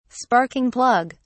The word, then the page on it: sparking plug: British English